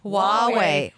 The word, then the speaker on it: Huawei: Huawei. H-U-A-W-E-I.